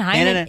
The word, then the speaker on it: Hainan: Hainan. H-A-I-N-A-N.